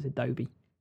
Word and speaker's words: Adobe: and it's Adobe.